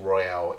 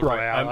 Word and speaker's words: Royal: It's not Battle Royale, right, I'd like to ask. – Let me fix it there slightly. Royale. He pronounced it wrong. It's Persona 5 Royal.